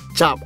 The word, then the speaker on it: cap: The thing is, the word chop is a variation from the Malay word chap, pronounced chap, okay? But we spell C-A-P, which is to stamp.